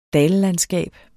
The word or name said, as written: dallandskab